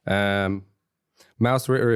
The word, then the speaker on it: Mausritter: Mausritter, if you don't know, is a game or pronounced properly Mausritter. – Oh, your pronunciation is getting good.